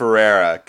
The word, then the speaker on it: Ferreira: Sky Ferrera or Sky Fiera? You pronounce it like Guy Fieri. – How'd you say it? Sky Ferrera.